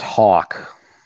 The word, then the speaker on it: Houck: It's Tanner Houck. Is that, am I right on that? – It's pronounced Hawk.